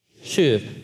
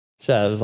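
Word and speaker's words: shuv (שוב): Is this Hebrew word shuv. Why don't you guys say it with me? Shuv. If you see it spelled, it looks like shove, like junior hires shoving something. So, but it's actually pronounced shuv.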